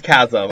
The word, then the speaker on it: chasm: I didn't know if you actually said chasm. I was worried. I was saying it wrong the whole time. – No, it's chasm. – It's chasm.